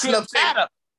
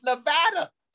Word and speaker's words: Nevada: It's Nevada. It's Nevada. I had to learn that the hard way, Pat. My producer says at halftime, hey, Houston, our phones are lighting up, our emails are lighting up, our Instagram's lighting up. They're wanting Coach Nutt to learn how to pronounce Nevada.